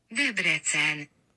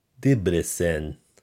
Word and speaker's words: Debrecen: Debrecen. – Debrecen, Hungary.